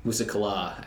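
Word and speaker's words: Musa Qala: Musa Kala, as it's correctly pronounced. Did I pronounce that correctly? – Yeah. – Everyone always pronounced it Musa Kala, right? – Yeah. – It's actually incorrect.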